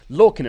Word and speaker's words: Lorcan: So we've got the Undisputed Era of Roddy Strong and Kyle O'Reilly, the War Raiders, Street Profits, Heavy Machinery, Lorcan and Birch. Did I say that right this time? – Yes. – Yes, I did.